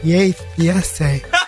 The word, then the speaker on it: Yay Fiesta: Please use the right name. – The amazing Weenie Roast Yay Fiesta. – Yeah, Weenie Roast Yay Fiesta. And I wondered why they wrote W-E-E-N-E-E. R-O-S.